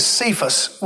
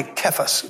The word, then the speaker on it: Cephas: one of the names is Cephas, which we pronounce likely incorrectly. It's probably Cephas, but we'll talk about that in a moment.